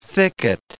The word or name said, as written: thicket